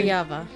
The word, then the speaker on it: Kirjava: Kirjava.